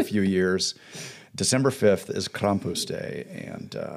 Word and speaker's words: Krampus: if you don't know who Krampus, that's a correct pronunciation there.